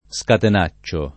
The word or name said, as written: scatenacciare